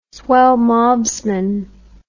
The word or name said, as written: swellmobsman